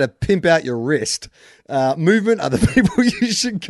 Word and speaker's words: MVMT: No, so it's not movement spelt out movement. It's MVMT, an abbreviation of movement that you just pronounce movement.